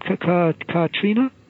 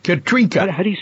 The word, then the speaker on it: Katrinka: You got it, and is your wife's name Katrina? – Katrinka. – Well, Katrinka. – Katrinka.